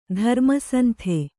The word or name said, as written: ಧರ್ಮಸಂತೆ